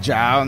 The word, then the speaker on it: J.A.U: ¿Cómo es la pronunciación correcta? Jao, ah, mira, si eres en, en gringo, pues Jao ¿no? Pues en, español es J.A.U. Es un acrónimo, Jao, justicia, aceptación, unidad.